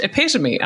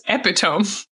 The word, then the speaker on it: epitome: but you're pronouncing it completely wrong. It's pronounced epitome. And I went, well, it shouldn't be because it's spelled epitome. Like, see, tome of a book. See, EpiPen, epitome.